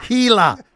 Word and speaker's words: Gila: Yes, Gila.